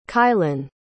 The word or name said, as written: kylen